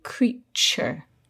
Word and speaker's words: creature: So we have this T-U-R-E at the end, but the T is not pronounced T, as in two. It's pronounced ch, like in cheese. So we say creature.